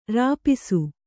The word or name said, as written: ರಿಪೀಸು